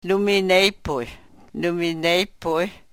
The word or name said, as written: numi nepuy